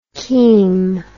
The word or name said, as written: chem